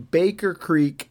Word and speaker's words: Baker Creek: And that is that I was pronouncing Baker Creek as Baker's Creek. Now, why I did that, I don't know, because I know that it is Baker Creek.